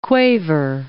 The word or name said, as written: quaver